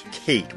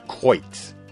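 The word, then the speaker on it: Cáit: By the way, I've been mispronouncing Kate, which is an anglicized version. The Irish's, I'll try, is closer to Coit.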